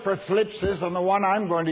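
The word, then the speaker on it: thlipsis: The Greek word for tribulation is one hard to pronounce. It's phlipsis. Got to watch your dentures when you pronounce that. Phlipsis.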